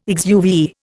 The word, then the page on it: exuviae: Indian